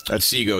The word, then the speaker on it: Otsego: Can you help me out with the pronunciation of this county? Otsego County? – Otsego, yeah. O-T-S-E-G-O, yes.